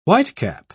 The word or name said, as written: whitecap